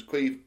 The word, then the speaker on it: Caimh: It's Irish. It's spelled C-A-I-M-H. – Wow. – So everyone gets his name wrong. – Yeah, of course. – But I've always been introduced to him as Cueve, I know.